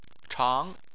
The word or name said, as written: chang2